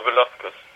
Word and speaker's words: Velasquez: Cain Velasquez. Oder wie spricht man das aus? – Ja, Velasquez.